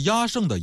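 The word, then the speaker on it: 厌胜: 压胜，这个压胜的压呀，就是讨厌的厌，胜呢就是胜利的胜。我们说这个压这个音啊，有的时候念成厌也是没错的。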